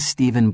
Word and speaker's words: Steven: Hello, I'm Stephen Black. – Hello, Stephen. How do you spell your first name? – S-T-E-V-E-N.